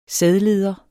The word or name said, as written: sædleder